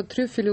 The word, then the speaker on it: трюфели: Что трюфели ударение на первом слоге в единственном во множественном числе. А мне казалось, трюфеля это так как-то изысканно.